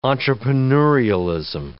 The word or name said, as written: entrepreneurialism